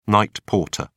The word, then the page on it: night porter: British English